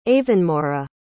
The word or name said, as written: avonmora